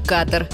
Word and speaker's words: Катар: Катар или Катар? Где ударение? Если речь идет о государстве на Аравийском полуострове, то, конечно, Катар с ударением на первый слог.